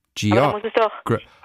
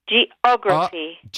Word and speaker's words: geography: Genau, ja, zum Beispiel bei Geography. Oder biological. – Geography. Geography. Das sind immer die drittletzte Silbe wird betont.